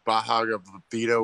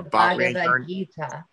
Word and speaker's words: Bhagavad Gita: Well, I just heard someone read out of the Bhagavad Gita, whatever. – The Bhagavad Gita. – Yeah, sorry, thank you. Didn't mean to butcher that.